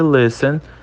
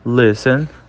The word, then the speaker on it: listen: Entendi que listen, listen, o T também é mudo, né? Porque antes do T vem a letra S.